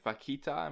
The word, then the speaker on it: vaquita: There was Save the Vaquita.